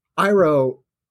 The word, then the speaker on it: Iroh: I always call him Iroh because Japanese pronunciation, excuse me, it's Iroh.